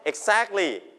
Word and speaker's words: exactly: exactly พยางค์ที่ 2 แล้วก็